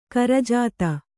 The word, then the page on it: ಕುರುಜಾತು: kurujātu; Kannada